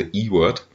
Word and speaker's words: Ewert: Ewert, also er äh, sagt, äh, er spricht sich normalerweise E-Word. E-Word. – E-Word. – Ja, genau. – Okay. So sprechen die Amerikaner das aus. Also nicht U-Word oder so ähnlich, sondern E-Word. Okay.